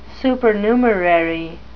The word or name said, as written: supernumerary